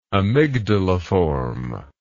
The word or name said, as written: amygdaliform